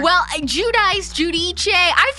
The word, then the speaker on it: Giudice: Liv says this is, you say Judice. I looked it up and it said the correct Italian pronunciation is Giudice.